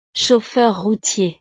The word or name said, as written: chauffeur routier